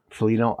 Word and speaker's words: Foligno: Foligno.